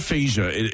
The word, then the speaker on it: aphasia: It's called aphasia.